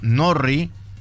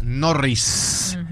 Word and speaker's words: Norrie: Cameron Norrie, no es Norris, porque mucha gente se confunde con Norris Landon, el corredor de Fórmula 1, los dos son británicos, pero este es Norrie. – Este Norrie.